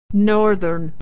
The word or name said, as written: northern